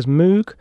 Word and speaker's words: Moog: A quick note on pronunciation, because obviously I'm saying it Moog when it's spelt M-O-O-G, and a lot of people would say it as Moog, but it's a Hungarian, apparently it's pronounced Moog, and as in spelled M-O-A-G, possibly, which sort of makes sense of the Moog Rogue, which was a later synthesizer of the company.